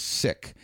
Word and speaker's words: Sikh: The correct pronunciation, according to them, is Sikh.